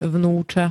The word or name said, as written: vnuca